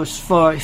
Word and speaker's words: sword: Die by your own sword. Felonious sword. You know, sword. Pronounced S-O-R-D. He says sword.